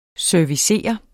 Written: servicere

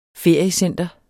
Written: feriecenter

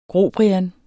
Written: GROBRIANS

